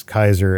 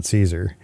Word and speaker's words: Kaiser: So apparently that's how he got that, was from knowing that the pronunciation was Kaiser and not Caesar.